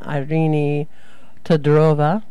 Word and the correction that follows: Irina Todorova: Could you say her name for me? – It's Irina Todorova.